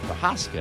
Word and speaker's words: Prochaska: Yes, I pronounced that correctly. That's the way they pronounce it. I've got relatives. Their name is pronounced Prochaska, and I think it's spelled the same.